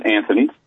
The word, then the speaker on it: Anthony: My middle name is Anthony. Last name is Wright, W-R-I-G-H-T. So Nicholas is spelled N-I-C-H-O-L-A-S, and Anthony, A-N-T-H-O-N-Y, and of course Wright, W-R-I-G-H-T.